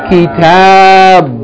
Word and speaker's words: kitabun: The first word should be read all together. Kitabun. Bun. Make sure you pronounce that N sound.